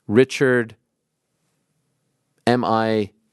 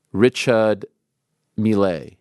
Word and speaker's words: Richard Mille: Can we get GC to stop pronouncing Richard M-I-L-L-E like that? It is pronounced Richard Mille rather than Richard Millet.